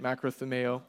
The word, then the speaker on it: makrothumeo: The primary word here is, or not the primary, the word for patience being used is makrothumeo, and it's how you say it. It's 100% how you say it, makrothumeo.